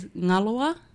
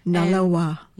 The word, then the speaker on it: Ngalawa: Yes, my son's name is Ngalawa. – Ngalawa. – Ngalawa. So it's spelled N-G-A-L-A-W-A. So it kind of rolls.